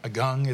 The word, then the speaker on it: Agung: Now, you were mentioning, I think people may be familiar in 2017 with the volcano Agung, is that how I pronounce mm-hmm.